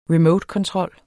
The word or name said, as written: remotekontrol